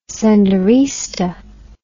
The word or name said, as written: senderista